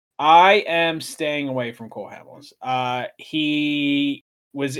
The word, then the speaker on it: Cole Hamels: Cole Hamels.